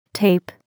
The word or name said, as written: tape